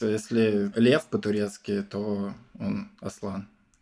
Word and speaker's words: Аслан: Я такой, а где все-таки будет правильное ударение на турецком? И оказалось, что в первоисточнике, если мы говорим про турецкие ударения, все-таки как Аслан оно читается.